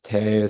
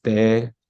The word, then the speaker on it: Te: Some people call it te, some people call it de or de.